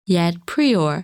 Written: Yed Prior

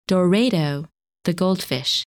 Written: Dorado

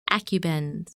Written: Acubens

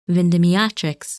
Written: Vindemiatrix

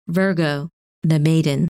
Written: Virgo

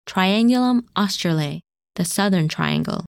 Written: Triangulum Australe

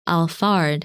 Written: Alphard

